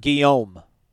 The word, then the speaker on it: Guillaume: Google says it's pronounced Robert Guillaume. – Guillaume. There you go.